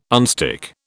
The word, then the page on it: unstick: British English